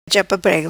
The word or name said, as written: Prêgo chapa